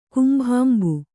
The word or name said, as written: ಕುಂಭಾಂಬು